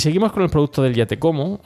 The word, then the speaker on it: Yatekomo: Eh, la marca es una marca muy creativa, como he dicho em, se pronuncia ya te como,